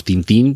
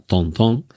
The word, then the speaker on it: Tintin: Eh, en el original, en francés, eh, no sé si he dicho que Hergé es belga y por tanto francófono y, todo, de hecho decimos Tintín, pero en realidad se pronunciaría Tontón pero nuestros Hernández y Fernández en, el original son Dupont y Dupont.